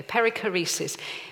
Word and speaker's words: perichoresis: It's pronounced something like perichoresis. Is that correct, David? Okay, perichoresis, which